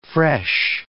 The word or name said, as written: fresh